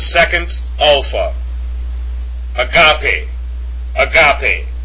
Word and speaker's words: agape: The next one that's popular to us is agape.